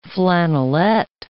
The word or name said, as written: flannelette